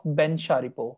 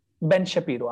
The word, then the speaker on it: Shapiro: So interestingly, Facebook suggested me a video of Ben Shapiro. I believe I'm taking his name correctly. Do you know him? – Ben Shapiro. – I'm sorry. I mispronounced it.